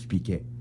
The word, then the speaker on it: Speakey: Effectivement, je travaille avec un poste adapté, de travail adapté qui est muni d'un logiciel qui s'appelle Speaky, S-P-E-A-K-E-Y, pour ceux qui ne savent pas comment ça s'écrit. Les Français, ils prononcent Speaky.